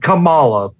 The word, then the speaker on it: Kamala: The Vice President of the United States, Kamala, and yeah, I said Kamala, like the wrestler, because that's how it's pronounced.